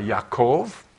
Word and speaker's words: Yaakov: And it says, so Israel, who is Jacob, or if you want to pronounce it properly, the J in Hebrew is like a Y, Yaakov.